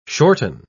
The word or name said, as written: shorten